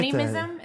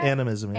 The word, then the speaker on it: animism: I get animism? – That. – Is that how you say it? – Animism.